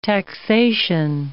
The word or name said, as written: taxation